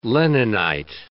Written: Leninite

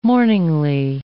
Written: mourningly

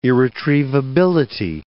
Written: irretrievability

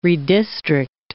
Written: redistrict

